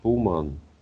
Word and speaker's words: туман: Как русское слово «туман», только ударение на «у» больше.